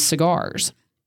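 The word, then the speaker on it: Seagar's: The name of the restaurant is Cigars, but somebody decided to spell that S-E-A-G-A-R apostrophe S or no apostrophe. But they spelled it S-E-A, but it's pronounced just like cigar, smoking a cigar, right?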